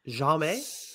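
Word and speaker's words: Jaume: Yep, I think you said it right. Jame? – Yeah.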